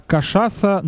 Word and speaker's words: Кашаса: а Кашаса на второе а ударение, да? Да, да.